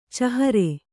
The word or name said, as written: ಚೇಹರಾ